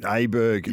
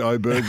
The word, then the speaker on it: Aberg: Aberg, not Oberg, but it is, in fact, Oberg. Listen to the commentary. – Well, that's why I asked the question at listen the start of the commentary, because it's spelt Aberg, but it's pronounced Oberg.